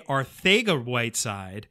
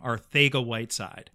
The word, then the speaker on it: Arcega-Whiteside: Arcega-Whiteside. – Ooh. – It is a Spanish pronunciation, like Spaniard Spanish, and it is the King's Spanish. So that is actually how JJ pronounces his last name, Arcega-Whiteside.